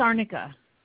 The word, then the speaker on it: arnica: So Sword No More Performance Liniment maintains the same, how do you say that word? Is it arnica? – Oh, yeah, it's arnica.